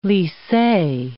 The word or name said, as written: lycee